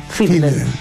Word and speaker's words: Fiedler: No, es que pasa es que la I y la E en alemán se pronuncia I nomás. Fiedler.